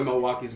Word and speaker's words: Milwaukee: I don't fuck with Milwaukee's best. No, they call it Milwaukee's worst. No. – It's pronounced Milwaukee.